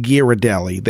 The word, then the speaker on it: Ghirardelli: I think it's pronounced Ghirardelli. The H is silent.